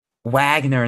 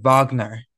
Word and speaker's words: Wagner: Gavin, I liked your new episode, except you kept saying, Wagner instead of Wagner.